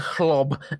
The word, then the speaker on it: Hloob: And by Dr. Klob, I mean it's spelled H-L-O-O-B. So it is actually Dr. Klob and Sherry.